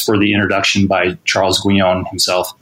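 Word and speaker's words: Guignon: And this little Hackett edition has an introduction by, I think his name is Charles Guyon, like G-U-I-G-N-O-N, I think is how his last name is spelled.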